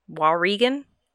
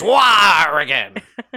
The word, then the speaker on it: Wauregan: W-A-U-R-E-G-A-N. Waurigan? – W-A-U-R-G-A-N? – R-E-G-A-N. – Waurigan. Waurigan. Waurigan. – Waurigan? – So this, yeah, it's like if Wario took over the state of Oregon and it was Waurigan.